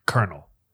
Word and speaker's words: colonel: Colonel. C-O-L-O. Like, dude, who the fuck decided that's how you say it? – Yeah.